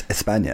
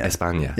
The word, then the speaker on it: España: Well, it's, but in Spanish it's España. – España, yeah, yeah, exactly.